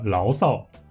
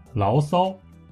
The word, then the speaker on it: 牢骚: 我们把这里的第二个字“ 骚” 读作轻声，也就是牢骚、发牢骚。不过，如果你愿意的话，你也可以把它读作牢骚。